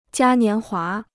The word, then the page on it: 嘉年华: jiā nián huá